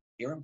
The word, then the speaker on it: harem: But actually, if you remember, there's a harem. Harem, is that the right pronunciation? – Harem. – Sounds like harem. That's why I always get confused.